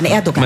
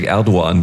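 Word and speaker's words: Erdoğan: Ja, Erdogan. Man lässt das G eigentlich weg, Erdogan, glaube ich.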